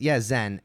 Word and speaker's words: Zhen: It is, Zhen. Z-H-E-N. – Okay. Okay, so they were pronouncing like a Chinese Shen. – Yeah, Shen.